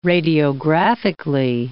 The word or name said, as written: radiographically